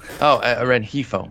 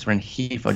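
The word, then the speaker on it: Rengifo: Rigifno guy, Rifigno. – Renjifo. Luis Renjifo. Just the G is an H.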